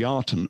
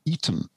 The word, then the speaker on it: Aten: It was called the Aten, or actually we should really pronounce it as being the Eton, a bit like the old public school. It was the Eton or the Eden.